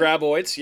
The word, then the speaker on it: Graboid: So, the Graboid. Is that, did I say it correctly? – The Graboids, yep, you said it.